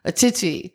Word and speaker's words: Atshitshi: Achichi. – Achichi. – Achichi. How do you spell that? – A-T-S-H-I-T-S-H-I. Achichi. – S-H-I-T.